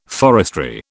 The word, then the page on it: forestry: British English